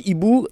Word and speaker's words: Eeboo: Donc Ibu, ça s'écrit E-E-B-O-O en plus.